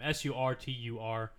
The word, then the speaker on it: Surtur: I mean, she was the main antagonist, but the baddest bad guy was Searcher. I can't say his name. Surtur, who essentially made Ragnarok happen.